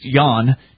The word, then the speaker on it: Jan: Anyway, apparently some libertarian TV guy who hosts his own, cable access show, Jan Helfeld, or I'm being told it may be pronounced Jan, J-A-N, Jan Helfeld.